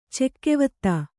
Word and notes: ಚೆಕ್ಕೆವತ್ತ: cekkevatta

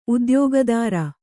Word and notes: ಉದ್ಯೋಗದಾರ: udyōgadāra

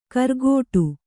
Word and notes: ಕರ್ಗೋಟು: kargōṭu; Kannada; dial.

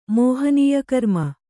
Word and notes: ಮೋಹನೀಯಕರ್ಮ: Kannada

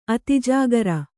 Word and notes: ಅತಿಜಾಗರ: Kannada

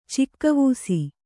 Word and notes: ಚಿಕ್ಕವೂಸಿ: cikka vūsi

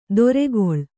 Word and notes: ದೊರೆಗೊಳ್: doregoḷ

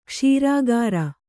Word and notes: ಕ್ಷೀರಗೌರ: kṣīra gaura

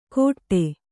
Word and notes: ಕೊಟ್ಟೆ: kannada

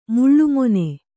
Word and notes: ಮುಳ್ಳುಮೊನೆ: Kannada

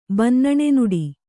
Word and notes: ಬನ್ನಣೆನುಡಿ: kannada

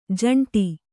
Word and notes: ಜಂಟಿ: jaṇṭi

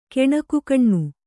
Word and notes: ಗಣಿಕೆಗಣ್ಣು: gaṇikegaṇṇu

